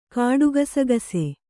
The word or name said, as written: ಕಾಡುಗಸಗಸೆ